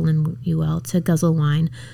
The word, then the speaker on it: Lemuel: Lemuel.